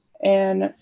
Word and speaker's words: Aodh: So A, it's spelled A-O-D-H, but it's just pronounced A. – It's just A.